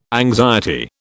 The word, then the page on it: anxiety: British English